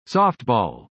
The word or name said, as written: softball